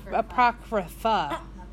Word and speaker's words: Apocrypha: My topic is the apocrypheth. – Apocrypheth? – Apocrypheth. – How do you say that? – Apocrypheth. Spell it. – Apocrypheth. – Can you spell it? – A-C-R-O-P-H-T-Y-A. – Apocrypha.